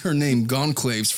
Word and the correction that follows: Goncalves: for the longest time. – Goncalves.